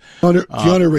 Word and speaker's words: Keanu: I think of Canal Reeves, the Matrix guy, Canal Reeves, right? Am I saying his name right? Canal Reeves? – Keanu Reeves. – Keanu. Keanu Reeves,